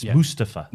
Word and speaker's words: Mustafa: Oh, and also, can we put some respect on his name as well by saying it right? It's Mustafa.